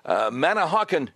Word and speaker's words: Manahawkin: Let's go to Ryan, listening on WABC from, I hope I'm saying this right, Manahawkin, New Jersey.